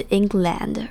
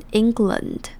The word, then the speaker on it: England: England 这边它的 A 是发成 E 的声音，所以并不是 England，而是念成 England。那它的重音在第一个音节 EN 那边， England。最后我们来复习一下今天的例句。 One study published in the journal Environmental Research Letters found that if the Arctic sea ice melts, England will probably get a little rainier。